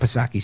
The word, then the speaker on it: Psaki: Anyway, let's continue with Jen Pasirko-Back Psaki.